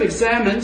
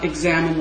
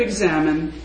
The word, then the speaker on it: examen: The prayer of examine, since you haven't seen it written down, the word is spelled E-X-A-M-E-N, not examine like an examination.